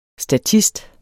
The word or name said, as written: statist